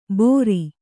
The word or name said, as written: ಬೋರಿ